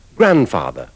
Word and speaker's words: grandfather: Invece nella pronuncia inglese di nonno, nonna e nipote, maschile e femminile, scompare la lettera D, o ne resta solo una, come noterete nell'ultima parola. – Grandfather.